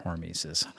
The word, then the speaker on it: hormesis: it's hormesis, right? – Hormesis.